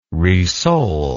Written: resole

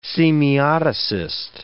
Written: semioticist

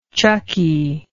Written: chucky

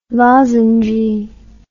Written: lozengy

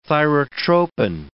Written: thyrotropin

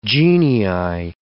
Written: genii

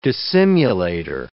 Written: dissimulator

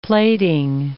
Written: plaiting

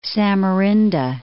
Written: Samarinda

